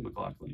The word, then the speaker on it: McLaughlin: Is that pronounced correctly? McLaughlin. – You nailed it.